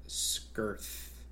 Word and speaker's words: Skirth: Jenny Slate plays Dora Skirth. Skirth. – Oh, her name is Skirth. – It's spelled Skirth. I guess they pronounce it Skirt. – They do. Mr. Ahmed pronounces it Skirt.